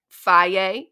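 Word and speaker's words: Fage: And then the yogurt, I only know how to say this because I've seen commercials, but I would Faye, that's the right way to say it, but it's F-A-G-E.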